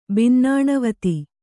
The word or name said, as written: ಬನ್ನಣೆವಾತು